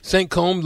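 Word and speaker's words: Saint-Cosme: Saint-Côme, like, is that like puffy combs? – Kinda, except it's spelled C-O-S-M-E.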